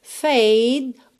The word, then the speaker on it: fade: Fade, o E final não é pronunciado, você para no D, que pode aparecer mais, menos ou nada.